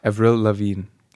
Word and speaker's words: Avril Lavigne: Avril Lavigne wird ja so ausgesprochen.